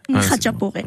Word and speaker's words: khachapuri: Khachapuri. – Ah, voilà. Ouais. Et mais on dit Khachapuri.